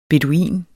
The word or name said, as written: BEDUINEN